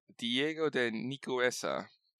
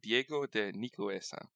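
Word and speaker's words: Diego de Nicuesa: Diego de Nicoesca. Sorry, I mispronounced it the first time. Diego de Nicoesca.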